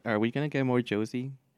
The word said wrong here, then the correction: Josei: Wait, is that how you pronounce it? Fuck, I'm butchering it. – Wait, what? – J-O-S-E-I? – Jose.